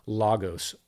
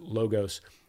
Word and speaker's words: Logos: This is called Logos, or other people pronounce it Logos, L-O-G-O-S.